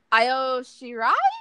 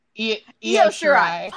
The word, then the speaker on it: Io Shirai: NXT Women's Championship, Shayna Baszler versus Kairi Sane versus Bianca Belair versus Io Shirai? I can never say her name correctly. – Io Shirai.